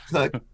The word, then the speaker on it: Koch: If you do put my name in, it is spelled K-O-C-H. It's right. Pronounced Cook, but you're going to want to Google Kathy K-O-C-H.